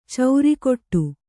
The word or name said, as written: ಚೌರಿಕೊಟ್ಟು